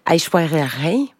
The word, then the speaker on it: Aishwarya Rai: Aishwarya Rai.